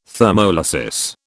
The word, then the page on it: thermolysis: British English